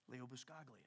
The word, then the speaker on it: Buscaglia: Leo Buscaglia.